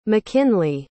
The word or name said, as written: makynly